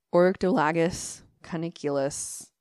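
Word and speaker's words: Oryctolagus cuniculus: You say it like English. – Orctolagus caniculus.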